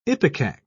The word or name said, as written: ipecac